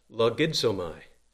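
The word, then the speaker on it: logizomai (λογίζομαι): It is the Greek word that is pronounced logizomai. We get our English word logic from it.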